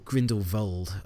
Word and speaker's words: Grindelwald: Also, Grindelwald, I should say, because it's a V instead of a W, pronounced Vold.